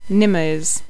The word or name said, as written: Nimmos